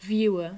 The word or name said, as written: viewer